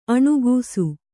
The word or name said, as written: ಅಣಕಿಸು